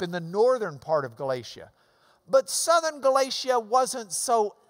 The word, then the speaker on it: Galatia: It's that hard C, hard K, hard G sound. Galatia.